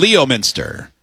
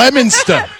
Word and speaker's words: Leominster: Leominster. – Leominster. – Leominster. – Oh, yeah. Stuff. It's definitely spelled like that. L-E-O, Minster. I see it. – Leominster. – Leominster. – Stop pronouncing the R so hard.